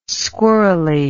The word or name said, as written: squirrely